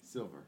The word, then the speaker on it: Silver: Is it Silver or Silva? – Silver.